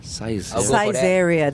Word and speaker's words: Saizeriya: Saizeriya. That's how it's pronounced.